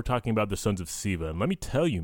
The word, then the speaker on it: Sceva: Sons of Sceva? – Yes, yes.